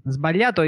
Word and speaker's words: sbagliato: Sbagliato is maybe difficult. The group GLI is pronounced gli. – Yeah, gli spaghetti. – Yeah, like gli spaghetti, yes.